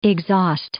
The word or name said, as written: exhaust